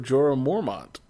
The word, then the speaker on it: Jorah Mormont: Sir Jorah Mormont.